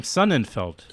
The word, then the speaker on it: Sonnenfeld: And interesting, so it's you pronounce your name Sonnenfeld, right? – I do.